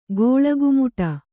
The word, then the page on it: ಕುಳ್ಳಕುಮ್ಮಟೆ: kannada